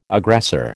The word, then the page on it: aggressor: American English